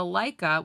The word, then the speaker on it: Leica: The Laika, which is spelled L-E-I-C-A, stands for Ernst Lietz Camera. So Lietz, L-E-I, and then the C-A of camera. So Lietz Camera became Laika.